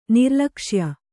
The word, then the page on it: ನಿರ್ಲಕ್ಷ್ಯ: nirlakṣya